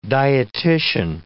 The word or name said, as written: dietitian